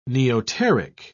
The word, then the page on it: neoteric: British English